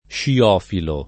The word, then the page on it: sciofilo: lingua italiana